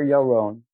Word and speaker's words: Yaron: Title of the book is Coffee, The Fourth Wave, A Fresh Roasting Revolution by Asher Yaron, A-S-H-E-R. My last name is spelled Y-A-R-O-N.